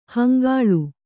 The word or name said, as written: ಹಂಗಳಿ